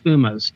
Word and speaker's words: Fumas: So it's the Rocky Patel has a whole line of, I think it's pronounced Fumas, F-U-M-A-S.